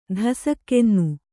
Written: ಧಸಕ್ಕೆನ್ನು

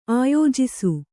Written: ಆಯೋಜಿಸು

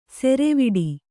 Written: ಸರವಟ್ಟಿ

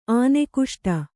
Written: ಆನೆಕುಷ್ಟ

